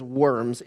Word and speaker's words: Worms: I know in your notes it says worms. It is written correctly and is pronounced Worms. It is a place in Germany.